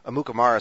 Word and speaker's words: Amukamara: And I commend you for pronouncing Prince Amukamara's name uh, correctly.